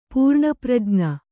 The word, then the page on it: ಪೂರ್ಣಪ್ರಜ್ಞ: pūrṇa prajṇa